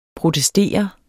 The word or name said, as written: protestere